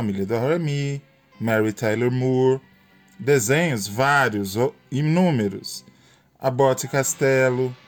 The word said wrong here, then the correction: Costello: Jackson 5, aliás, Abbott Costello, falei errado o nome.